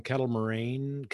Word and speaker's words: Kettle Moraine: And you and I know you just came back from Kettle Moraine.